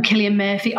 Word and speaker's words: Cillian Murphy: So I feel like that, I feel like I really fancy Cillian or Killian, I don't know how to say his name right, but Cillian Murphy. – Oh, Killian Murphy.